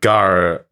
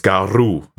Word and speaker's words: Garw: But I decided to go for a place called, well, so to me, with my English eyes, it looks like Gar Valley, but the Welsh pronunciation is Garw Valley. That's how it's supposed to be pronounced in Welsh, with a Welsh accent.